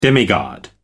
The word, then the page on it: demigod: American English